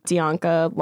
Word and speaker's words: Dianca: So you could probably just Google my name, Dianca London. It's spelled like Bianca, but with a D.